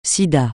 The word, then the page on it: Sida: francais